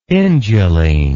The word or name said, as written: induline